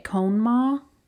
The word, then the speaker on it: Conemaugh: So the town was abandoned in the 50s because they started to construct a dam on the side of, I think that's how you say it, Conemaugh. It's C-O-N-E-M-A-U-G-H, Conemaugh.